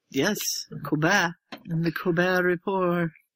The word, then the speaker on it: Colbert: It's like Colbert. You don't pronounce the T like Voldemort.